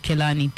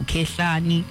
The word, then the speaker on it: Kehlani: Many people always pronounce igama lakhe Kelani differently, other than saying Kehlani.